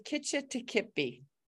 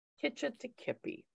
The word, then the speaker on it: Kitch-iti-kipi: And she is the author of The Legend of, can you pronounce it for us so we all say it the right way? – It's The Legend of Kitch-iti-kipi. – Kitch-iti-kipi.